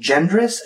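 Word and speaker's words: Jendras: I believe you pronounce his last name Gendras. It's J-E-N-D-R-A-S.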